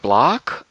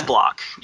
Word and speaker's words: Blach: It's spelled B-L-A-C-H. Is it Block? – Yeah, it's Block. Yeah. Block.